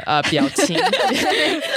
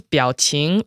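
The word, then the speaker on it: Biaoqing (表情): but Baoxing? Or how do I say it? – Yeah, Biaoqing. – Clearly, I've met my match in this. – So the term is Biaoqing, and it's usually used as the phrase Biaoqing Bao, which sort of means like bag of Biaoqing.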